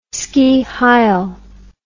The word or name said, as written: ski heil